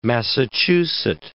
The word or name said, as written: Massachuset